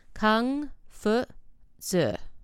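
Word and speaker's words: Kung Fu Zi (孔夫子): Kung Fu. Zi.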